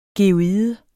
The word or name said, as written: geoide